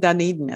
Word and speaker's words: Dunedin: He's living in Dunedin at the time.